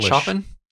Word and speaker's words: Chopin: Is it not pronounced Chopin? – No, it is.